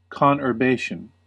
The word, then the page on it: conurbation: American English